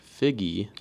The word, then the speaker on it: Figgy: And so if you pronounce F-I-G-I, it kind of sounds like figgy.